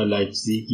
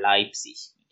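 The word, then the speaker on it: Leipzig: توی لحجه های مختلف توی آلمانی متفاوت تلفظ میشه تلفظ میار زیک میگه ولی اکثر تلفظ که اکثر گویش ها مثلا لایپسیش میگن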